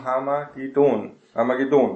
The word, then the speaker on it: Harmageddon: Es geht um Harmageddon, ne? Die Betonung auf der letzten Silbe. Wir merken schon, dass hier auf Deutsch, ne, man aufrutscht auf diese Aussprache, Harmageddon oder so, ne?